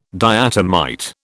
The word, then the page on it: diatomite: British English